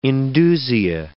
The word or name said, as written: indusia